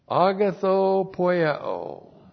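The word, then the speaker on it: Agathopoieo (ἀγαθοποιέω): Agathopoieo. That's A-G-A-T-H-O-P-O-I-E, long O.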